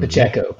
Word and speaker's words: Pacheco: Pacheco, Pacheco, yeah, uh, he's made a lot of noise.